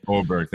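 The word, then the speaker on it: Åberg: Aberg, I believe it's how you pronounce it. Oberg, thank you. Yeah, that's right.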